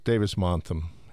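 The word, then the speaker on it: Davis-Monthan: I don't want to say this wrong, Montham? – Davis-Montham.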